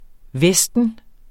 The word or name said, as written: vesten